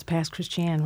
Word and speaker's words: Pass Christian: So first, if you would talk a little bit about the fact that you had an historic home in Pass Christian that was destroyed in Katrina, yes? – Yes, and you pronounce Pass Christian right, correctly.